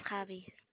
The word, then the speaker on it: Chavie: My name is Chavi.